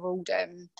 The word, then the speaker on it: Aldham: It's just just my name at Rebecca Aldham.